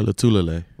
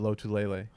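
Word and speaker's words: Lotulelei: Star Latulele. – Latulele. – Yeah. – See, I think for years I've always said Lotulele. That's not right.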